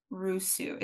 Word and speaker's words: Rusu: Her name is Meredith Rusu.